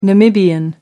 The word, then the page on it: Namibian: American English